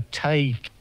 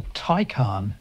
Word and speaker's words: Taycan: I might have said a Taycan, but it's not that, is it? – No, it's a Taycan. – Well, T-A-Y-C-N.